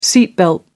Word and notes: seat belt: American English

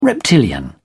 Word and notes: reptilian: British English

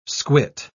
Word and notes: squit: British English

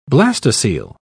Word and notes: blastocoel: British English

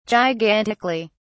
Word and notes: gigantically: British English

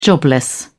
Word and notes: jobless: British English

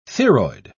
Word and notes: theroid: British English

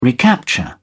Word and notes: recapture: British English

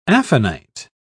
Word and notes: aphanite: British English

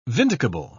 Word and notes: vindicable: British English

